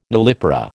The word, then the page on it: nullipara: American English